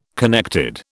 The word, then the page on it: connected: British English